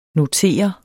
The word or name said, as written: notere